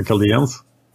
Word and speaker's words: Chaldeans: the Chaldeans that I pronounce differently every time, but I think the correct English pronunciation is the Chaldeans, right? – Yeah, that's what I'm going with.